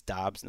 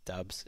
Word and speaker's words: Doubs: If Doubs is starting, and I did learn over the last week it's Doubs, not Dubs, and you guys have been saying it right. Apparently I was saying it wrong.